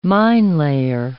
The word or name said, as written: minelayer